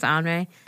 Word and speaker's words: Henri: Remember, it looks like Henry. The word looks like Henry, but it's pronounced Henri.